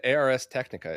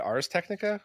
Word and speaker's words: Ars Technica: I always said ARS Technica. Is it Ars Technica? – Ars Technica, it means the art of technology.